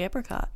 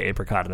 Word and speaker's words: apricot: No, it's the apricot. – Yeah. Now, we're going to probably interchangeably say apricot and apricot because you and I pronounce it differently. Both ways are acceptable.